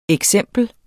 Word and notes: eksempel: [ εgˈsεmˀbəl ]; [ eg- ]

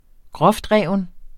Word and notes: groftreven: -ˌʁεˀvən

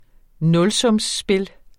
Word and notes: nulsumsspil: ˈnɔlsɔmsˌsbel

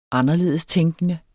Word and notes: anderledestænkende: [ ˈɑnʌˌleðˀəsˌtεŋgənə ]; [ -ˌtεŋˀgənə ]

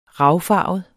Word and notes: ravfarvet: [ -ˌfɑˀvəð ]; [ -ˌfɑːvəð ]

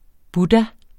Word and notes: buddha: ˈbuda

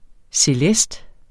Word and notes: celest: seˈlεsd

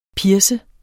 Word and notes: pierce: ˈpiɐ̯sə

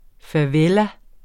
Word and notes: favela: faˈvεla; faˈveːla

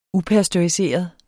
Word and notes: upasteuriseret: ˈupasdoɐ̯iˌseˀʌð; ˈupasdʌi-

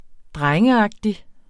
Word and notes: drengeagtig: ˈdʁaŋəˌɑgdi

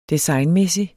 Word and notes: designmæssig: [ -ˌmεˀsi ]; [ -ˌmεsi ]

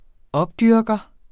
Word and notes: opdyrker: -ˌdyɐ̯gʌ; -ˌdyɐ̯ˀgʌ

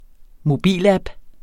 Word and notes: mobilapp: -ˌab; -ˌɑb

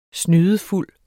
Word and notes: snydefuld: [ ˈsnyːðəˈfulˀ ]; [ ˈsnyːðəˌfulˀ ]

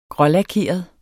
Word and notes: grålakeret: ˈgʁʌlaˌkeˀʌð; ˈgʁʌlɑˌkeˀʌð

